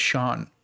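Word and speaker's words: Siân: But anyway, the film was written and directed by Sean Hedder, which the only reason I know how to say this name is because of Taskmaster, believe it or not. S-I-A-N. Her name is Sean.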